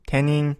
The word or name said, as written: tenin